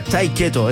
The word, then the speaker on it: Tiqueto: Esto es un grupo que se pronuncia Tiqueto, es como Tate Quieto. Es así, como pegar un Tate Quieto.